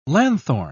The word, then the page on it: lanthorn: British English